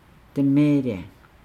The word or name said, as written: demeelaa